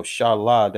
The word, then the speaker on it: Shalah: So Shalah, that's how you say my name in the Hebrew, taking those words and put them together.